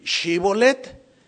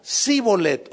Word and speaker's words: Shibolet: Si él respondía no, entonces le decían: a ver, ahora pues di Shibolet. Y él decía Sibolet, o sea, no podían pronunciarlo, lo cual denunciaba que eran del otro equipo o de la otra tribu.